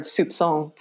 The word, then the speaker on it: soupçon: Oh, I recently heard soupçon for the first time. And let me tell you how grateful I was to have never encountered that in a conversation in which I had to say it first. Hard C.